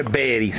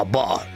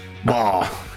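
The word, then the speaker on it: Bari: Poi non si dice Beri, sai che c'è questa grandissima, questo grandissimo equivoco che la Lino Banfi, che è un grandissimo artista, ha creato questa cosa Beri. Invece no, la Bar. Bar.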